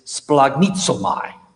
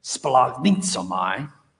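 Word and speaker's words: splagnitzomai (σπλαγχνίζομαι): And here the word being translated compassion is splagnitzomai. Now, it has a guttural sound in your throat when you say it right. You have to almost spray it when you say it. Splagnitzomai.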